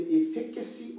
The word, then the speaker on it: efficacy: So I was praying, and the Lord dropped in my heart to encourage the Ignatian Church on the topic, the efficacy of a godly dream. The efficacy. E-F-F-I-C-A-C-Y. The efficacy. How do they pronounce it? Efficacy. Yes, am I right? – Yeah. Oh, yeah. Close enough.